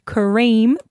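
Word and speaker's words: K'Reme: She said, my son has a classmate named Kareem, pronounced K-A-R-E-E-M, except it's spelt K apostrophe R-E-M-E. Like Krispy Kreme, almost.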